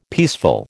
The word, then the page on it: peaceful: American English